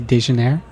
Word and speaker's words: déjeuner: I mean, how do you say déjeuner? – Déjeuner, yeah.